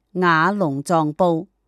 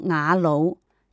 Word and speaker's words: 雅鲁藏布: 西藏曲水一带藏语叫做雅鲁，该江流至山南一带叫做雅龙，因此才称这条河为雅龙藏布，但在曲水地区念作雅鲁，因为鲁的藏语确切的语音称龙，意思就是从曲水以上流经河谷平原的河流，所以全段河流总称为雅鲁藏布江。